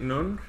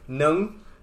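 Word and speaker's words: nướng: Now that is funny, because I don't know the correct pronunciation, but you might be murdering it, because I learned recently, if you say what you said, you're saying horny meat noodles. – Okay. – So, nung. – Yes. – Is horny. Grilled is nung.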